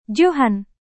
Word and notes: johan: Indonesian

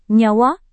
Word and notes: nyawa: Indonesian

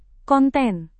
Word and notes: konten: Indonesian

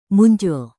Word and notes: muncul: Indonesian

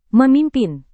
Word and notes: memimpin: Indonesian

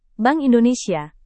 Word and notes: bank indonesia: indonesian

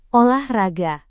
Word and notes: olahraga: Indonesian

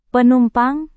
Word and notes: penumpang: indonesian

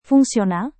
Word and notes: fungsional: indonesian

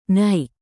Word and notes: naik: indonesian

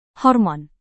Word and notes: hormon: Indonesian

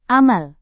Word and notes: amal: indonesian

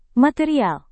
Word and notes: material: Indonesian

